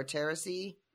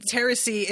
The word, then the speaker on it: Therese: Her name is spelled like T-H-E-R-E-S-E, but she's from Nantes. – Oh, Teresi. I think is how she says it in the audiobook.